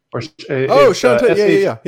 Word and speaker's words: Shantae: I think it's pronounced Shantae. – Shantae. Yeah.